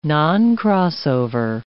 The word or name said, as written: noncrossover